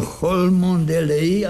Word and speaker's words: Cholmondeley: Píše se to Cholmondeley a čte se to Cholmondeley.